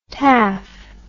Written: taff

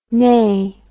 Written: neagh

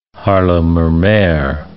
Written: Haarlemmermeer

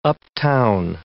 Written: uptown

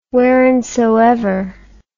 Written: whereinsoever

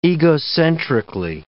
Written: egocentrically

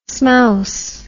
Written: smouse

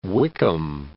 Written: High Wycombe

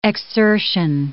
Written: exsertion

